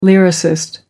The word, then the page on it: lyricist: American English